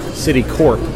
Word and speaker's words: Citicorp: You know, because there was Citibank, and the company of Citibank was Citicorp. I think it was Corp, not Core. You know what I mean? Like, sometimes C-O-R-P-S is pronounced Core, but I think C-O-R-P is pronounced Corp.